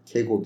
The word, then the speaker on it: Kierkegaard: Bu arada Kierkegaard Kego diye okunuyormuş.